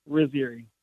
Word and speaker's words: Rizzieri: It's, I always tell people, think about Lake Erie, Rizziari.